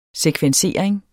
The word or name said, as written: sekvensering